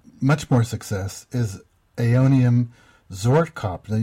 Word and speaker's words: Zwartkop: Zwartkop, yes, is the name. I've also heard it described as, or called zwartkop, but zwartkop is the original Dutch name of the cultivar.